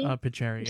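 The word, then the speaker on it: Peteri: Troy Peter, as letters. I hope I said that right. Pateri, maybe? – Pateri, yeah. Sorry. Pateri.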